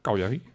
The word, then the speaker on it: Cagliari: Zo spreek je het dus uit? – Cagliari. – Oké. – Okay, ja, je dacht Cagliari.